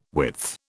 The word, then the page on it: width: British English